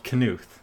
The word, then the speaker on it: Knuth: Pronounce the K in Knuth. Oh my god, I've been saying this wrong the entire time. Excuse me, Donald Knuth.